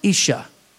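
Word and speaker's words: Isha (אשה): I believe the Hebrew word for woman is pronounced Isha.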